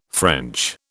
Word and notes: French: British English

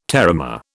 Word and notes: terramare: British English

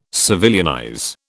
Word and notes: civilianize: British English

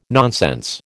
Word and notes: nonsense: American English

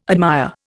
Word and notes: admire: Indian

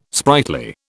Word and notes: sprightly: British English

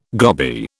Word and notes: goby: British English

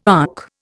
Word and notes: ankh: Indian

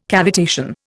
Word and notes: cavitation: Indian

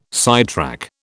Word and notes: sidetrack: British English

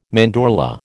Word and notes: mandorla: American English

